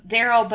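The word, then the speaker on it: Derol: No, so it's Daryl, but it's spelled like that.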